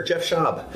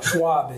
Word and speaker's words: Jeff Schaub: Schwab is the usual. – Schwab? – Schwab is the usual mispronunciation.